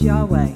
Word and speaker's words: Yahweh: In Hebrew, over 6,000 times, we see his name is not his title of Lord, but four letters, YHWH, usually pronounced as Yahweh.